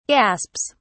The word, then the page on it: gasps: British English